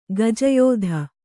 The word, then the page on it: ಗಜಯೋಧ: kannada